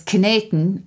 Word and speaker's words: kneten: So it would have been, the German is kneten, and we would have talked about kneading, which I love.